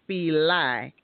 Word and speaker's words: Fila: And you say Fila, but I'm from the South, so we say Fili. So I'm probably not pronouncing it correctly. – Oh, interesting. – But we say Fili down here, like L-I-E.